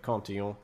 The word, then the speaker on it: Cantillon: Well, I suppose you should pronounce it the French way. Cantillon, maybe. And he posited that the flow of money as it's injected into the economy is non-neutral.